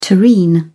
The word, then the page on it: terrine: British English